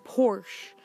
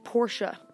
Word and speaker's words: Porsche: And I know some people call it Porsche, but the correct pronunciation is Porsche. You kind of add the sound at the end, and it is the true way to say Porsche.